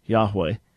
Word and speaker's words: Yahweh: No, the Y is a Y sound in Hebrew, Yahweh, just the way it would look as spelled.